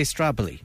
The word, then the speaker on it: Stradbally: Are you Strably or Stradbally, man? – Well, the locals say Strably.